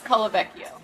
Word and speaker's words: Colavecchio: It's Colavecchio.